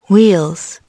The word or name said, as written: wheels